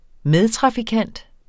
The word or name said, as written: medtrafikant